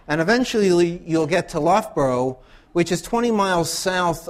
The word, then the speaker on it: Loughborough: If I could pronounce it correctly, I would say Loughborough. Loughborough. Okay.